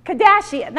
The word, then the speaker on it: Kardashian: Another Kardashian, right? I mean, am I saying that right? Kardashian, that's it.